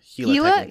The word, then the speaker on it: Gila: I think it's Gila. – Gila River Arena? All right. – Yeah, because it's the same word as the Gila Monster, which is a lizard in Arizona.